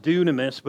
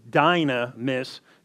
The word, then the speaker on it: dunamis: It's actually pronounced dunamis, but it looks like D-Y, you know, N, dune, it's dynamis is how it looks, but it's actually dunamis.